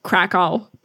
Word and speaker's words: Kraków: But all the pierogi spots, you got to go to Kraków. So Krakow, because Americans say it differently.